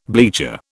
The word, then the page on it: bleacher: British English